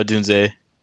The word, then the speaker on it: Odunze: and is it Odunze? Is that how you say it? Or how do you say his name? – Go ahead. – Yeah, Odunze. Yeah.